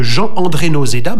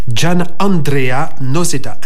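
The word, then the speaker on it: Gianandrea Noseda: L'Orchestre symphonique de la radio de Francfort est placé sous la direction de Jean-André Nozeda, mais ça ne se prononce pas comme ça, Gian Andrea Nozeda, un disque Naïve.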